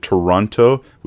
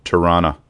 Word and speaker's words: Toronto: We don't pronounce it Toronto. We pronounce it Tirana.